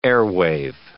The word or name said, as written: airwave